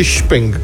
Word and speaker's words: Xpeng: Numele constructorului este chinez, este Xpeng, într-un cuvânt, cu mix se pronunță și, la ei, deci e Xpeng.